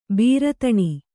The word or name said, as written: ಭೀರುತನ